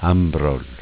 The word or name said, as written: Ambroll